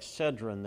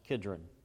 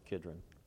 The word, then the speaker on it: Kidron: It looks like Cedron there, but it's pronounced Kidron. You usually pronounce it Kidron.